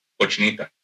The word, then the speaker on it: Cochinita: and correct me if I'm saying this wrong, I believe it's Conchitas de Pabil. Mm-hmm. Is that so? – It's Cochinita.